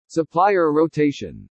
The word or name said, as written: supplier rotation